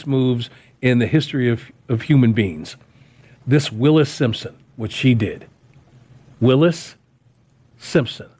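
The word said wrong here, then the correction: Wallis Simpson: Or Wallace. Wallace Simpson. Wallis. Yeah. – That sounds right.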